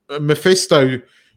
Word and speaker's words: Mephisto: Mephisto